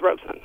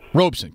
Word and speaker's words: Robeson: What about the idea, and I've read it, and I'm not sure I disagree with it, that Paul Robeson, is that how you pronounce his name? – I think it's Robeson. – Robeson, correct.